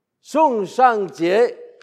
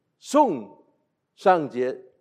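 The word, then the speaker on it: Song Shangjie: Sung Shang Jie. This is Mandarin. I'm not Mandarin educated, but I know how to pronounce his name properly. Sung Shang Jie.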